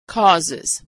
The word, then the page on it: causes: Iz